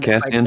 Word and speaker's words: Kassian: Cat is Cat Kassian's how you pronounce it, right? The the one Kassian. That, yep.